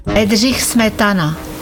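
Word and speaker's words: Bedřich Smetana: pravilno izgovorijo uh, priimek Bidžiha smetana. Okay. – Bedrih smetana. Bedrih smetana.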